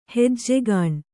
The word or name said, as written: ಹೆಜ್ಜಿಗಣೆ